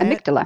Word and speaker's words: amygdala: So can you impact your amygdala? Is that how you say amygdala.